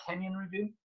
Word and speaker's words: Kenyon Review: Did I say that right? Kenyon Review? – That's right, yeah.